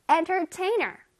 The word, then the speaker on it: entertainer: 자, 엔터테이너 할때 엔터의 강세가 들어가고 끝에 ER도 좀 뚜렷하게 발음해줘야 돼. 엔터테이너, 엔터테이너. 할 수 있겠죠? 따라라라. Entertainer.